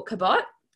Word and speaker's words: Cabot: or Cabot, I should say, was how it was pronounced. I've always pronounced it Cabot, but apparently it is Cabot.